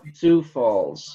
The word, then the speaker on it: Sioux Falls: Is out of St. Falls, South Dakota. – Sioux Falls. – Sioux Falls, South Dakota, which is spelled S-O-U-I-X. S-I-O-U-X.